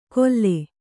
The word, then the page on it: ಕೊಲ್ಲೆ: Kannada